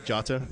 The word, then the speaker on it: Jota: Jota? Yeah, they actually do pronounce the J.